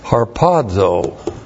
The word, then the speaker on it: harpazo: And it translates the Greek word harpazo. H-A-R-P-A-Z, long O.